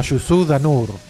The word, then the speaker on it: Youssou N'Dour: Nos estamos refiriendo a Youssou N'Dour, así se pronuncia, ¿no? Youssou en francés, pero es senegalés, pero los senegaleses son medio franceses.